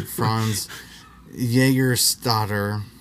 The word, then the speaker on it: Jägerstätter: The Austrian Franz Junkmeister, that's not how you say his name, Franz Jägerstätter, a conscientious objector, refused to fight for the Nazis in World War II.